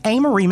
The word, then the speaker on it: Amory: Before we sit down and chat with Amory. Am I saying that right? It's Amory, Mississippi, right? – Yeah.